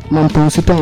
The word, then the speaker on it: Mampong-Nsuta: Which is Mampong-Nsuta in the central region of Ghana.